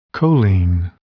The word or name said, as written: choline